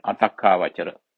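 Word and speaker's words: atakkāvacara: Atakkavachara, long word, Atakkavachara. Takka here. A-T-A-K-K-A, long A, V-A-C-A-R-A. A plus T-A-K-K-A, Takka. A-V-A-C-A-R-A, Avachara. A plus Takka plus Avachara. A plus Takka, T-A-K-K, Takka plus Avachara, A-V-A-C-A-R-A, Avachara.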